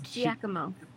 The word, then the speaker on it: Giacomo: It's Giacomo. – Giacomo? – I know that from a smut story, okay? That's the only reason I know that. – Giacomo. – Uh-huh. You pronounce it like Jack.